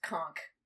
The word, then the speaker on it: conch: Always been told that it's pronounced conch, like the and ch is a k sound for some reason,